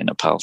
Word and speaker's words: Nepal: And also the fact that I knew I wanted to spend some more time in Nepal, or Nepal, depending on how you say it. – Yes. – The locals say Nepal. So we'll go with Nepal for now.